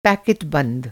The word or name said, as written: पै